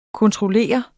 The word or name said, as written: kontroller